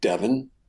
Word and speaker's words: Devon: So do you remember, we don't see a lot of Devon, the lost. – Devon, because she's explicit that his name rhymes with Levon from the Elton John song.